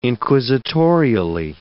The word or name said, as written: inquisitorially